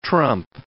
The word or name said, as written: trump